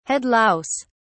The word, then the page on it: head louse: British English